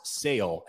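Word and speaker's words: Šalé: Now, it's pronounced Sale,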